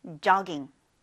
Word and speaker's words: jogging: 우리 조깅을 영어로 얘기할 때는 조라고 하지 말고 오 발음보다는 약간 어 발음으로, 열린 발음으로 jogging이라고 합니다. 자, 따라해보세요. jogging. 네, 다시 한번 jogging.